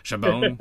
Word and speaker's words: Chabon: Chabon, Chabon.